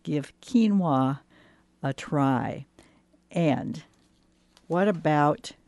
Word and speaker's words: quinoa: And if you're looking for more information, looking for it on the shelf, remember that it's spelled Q-U-I-N-O-A and it's pronounced quinoa.